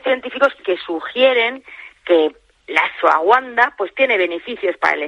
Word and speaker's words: ashwagandha: se llama ashwagandha. Vale, en realidad se escribe ashwagandha, pero bueno, es la ashwagandha,